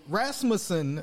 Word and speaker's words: Rasmussen: So Rasmussen, is it? Did I say that right? Mm-hmm.